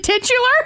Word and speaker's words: titular: I thought it was titler. – Oh. – Oh, sweet Lily. – Is it titular? – Oh, poor Lily. – There's another one I've been pronouncing wrong.